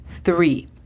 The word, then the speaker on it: three: Three. Tres. La pronunciación fonética sería T-H-R-I. Hacemos el sonido de la TH colocando nuestra lengua entre los dientes y soplamos. Three. Four. Cuatro. La pronunciación fonética es F-U-O-R.